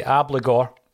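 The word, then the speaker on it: Abildgaard: I'm going to say Ablagor, because apparently that's how you pronounce it, Maeda, Forrest, and Ralston.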